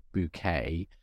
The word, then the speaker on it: Bucket: and plays this character called Hyacinth Bouquet, but a bouquet is in like a bouquet of flowers, but her surname is spelt Bucket, so it's Bucket, but she has everyone pronounce it Bouquet because she's trying to keep up appearances, hence the title of the show.